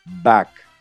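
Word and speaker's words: Bakkt: Y finalmente, la compañía Trump Media, que es propiedad del presidente electo Trump, está en conversaciones de comprar la plataforma de criptomoneda BACK. BACK se escribe B-A-K-K-T, B-A-K-K-T.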